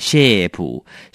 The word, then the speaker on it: shape: A 一定要念成 A E 两个很快的连在一起 A A 不能只有念 A 就念起来很不像 shape shape 这样很可怕是 shape shape